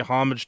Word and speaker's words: homage: It said that they were designed to pay homage to wild creatures and domesticated animals. – You go homage, not homage? – Yeah, it's a silent H.